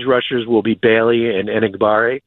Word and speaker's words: Enagbare: Now, is he going to start and split reps with Kingsley Enagbare? I believe that's how they pronounce it. Enagbare, I believe it is how you pronounce it.